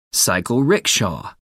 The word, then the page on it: cycle rickshaw: American English